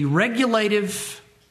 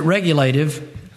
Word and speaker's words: regulative: and that is the regulative principle of worship. Now, I pronounce it regulative. To emphasize the word regulate, it's often said regulative principle of worship.